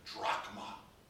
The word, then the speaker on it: drachma: The word in the original language is pronounced drachma.